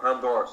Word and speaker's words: Palme d'Or: Like the Palme du Jour Award? I don't know. – Palme d'Or, sorry.